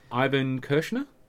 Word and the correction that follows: Irvin Kershner: Irvin Kershner, yeah, sorry, yes.